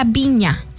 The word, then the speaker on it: Vigna: No sé, dime tú, se pronuncia Viña. Flor Viña, porque se escribe Vigna, pero es Viña, ¿verdad?